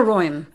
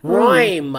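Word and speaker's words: Reum: Carter Roym. – Roym. – I mean, I actually know this guy, and I still have trouble with his last name. Reem. Carter Roym. – Roym.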